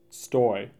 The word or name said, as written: Stoy